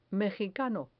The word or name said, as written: mejicano